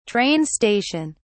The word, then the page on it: train station: British English